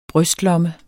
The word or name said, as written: brystlomme